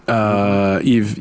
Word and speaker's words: Yves: Yves, yeah, Yves Chiron, but it'd be okay. Yves, Y-V-E.